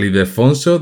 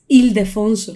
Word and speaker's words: Ildefonso: Este proyecto lo empezaron, y a ver si soy capaz de decirlo de seguido, en la iglesia de San Ildefonso del convento de las Trinitarias Descalzas de Madrid. Creo que lo he hecho bien. – Ildefonso. – ¿Cómo se dice? – Lo has dicho raro. Es Ildefonso.